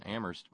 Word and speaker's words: Amherst: If you now here this is fairly new out, courtesy of research at the University of Massachusetts in Amherst, where only the H is silent.